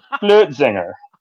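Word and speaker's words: Flötzinger: It's got the umlaut over the O, and I always sucked at that part. F L O umlaut T Z I N G E R. Flotzinger.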